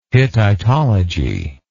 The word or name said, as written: hittitology